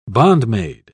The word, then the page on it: bondmaid: British English